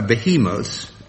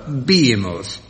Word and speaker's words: behemoth: Now, this is difficult because there are two ways of pronouncing it, and I'll try both. It's a behemoth, B-E-H-E-M-O-T-H, or a behemoth, spelt the same way. – I'll settle for behemoth, Jack. It's a biblical Old Testament word.